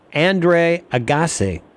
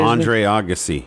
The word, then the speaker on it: Agassi: And Andre Agassi. Is that how you say it? – Andre Agassi. – Agassi. – Yeah, you really screwed that up.